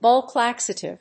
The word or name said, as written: bulk laxative